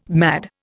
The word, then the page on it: mad: Indian